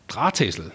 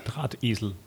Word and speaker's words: Drahtesel: Drahtesel. Drahtesel.